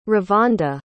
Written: revonda

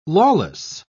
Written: lawless

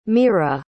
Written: meera

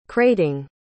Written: crating

